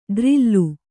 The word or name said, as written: ಡ್ರಿಲ್ಲು